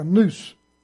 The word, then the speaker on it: nous: Now the word mind is the word N-O-U-S. And it's pronounced like a noose. Think of a noose around your neck.